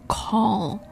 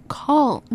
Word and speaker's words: call: 中文那个考试那个字考、 but it's not that 不是这个音，标准的发音是 call. Yes, very good. 你最后那个 L 的结尾 very good, 也许是因为我们刚才练 available. But call, very good.